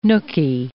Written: nooky

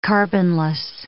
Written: carbonless

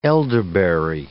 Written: elderberry

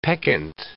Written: peccant

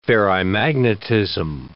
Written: ferrimagnetism

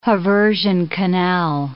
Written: haversian canal

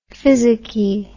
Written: physicky

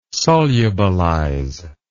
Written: solubilize

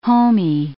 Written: homey

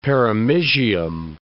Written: perimysium

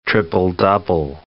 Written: triple-double

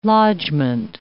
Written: lodgment

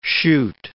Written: Shute

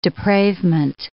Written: depravement

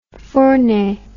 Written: Fournet

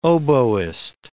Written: oboist

